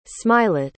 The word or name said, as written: smilet